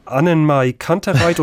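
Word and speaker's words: AnnenMayKantereit: Roxanne von Annenmay Kantereit und Milky Chance.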